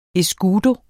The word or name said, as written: escudo